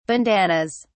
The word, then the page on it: bandannas: British English